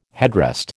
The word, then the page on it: headrest: American English